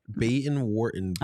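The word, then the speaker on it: Baden-Württemberg: Here's how you say it. – Baden Wartenberg.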